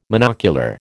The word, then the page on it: monocular: American English